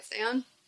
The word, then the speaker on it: Saiyan: Am I saying that right? Saiyan. – Super Saiyan. – There you go.